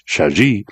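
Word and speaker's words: Chagee: And to resist a tendency to mispronounce Shaji, the G is soft.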